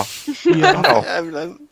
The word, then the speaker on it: Fire Emblem: Fire Emblem. Das ist so, spricht man es so aus, ja, ne? – Ja. Doch, ja.